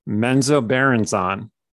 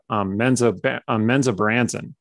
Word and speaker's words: Menzoberranzan: I always pronounced it growing up, Menzoberranzan. – That's how I was pronounced growing up as well. – But when you hear Bob pronounce it, it's Menzoberranzan.